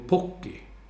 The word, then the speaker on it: Pukki: Han sa U blir O på finska. Så då ska man säga Teemu Pukki.